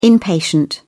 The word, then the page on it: inpatient: British English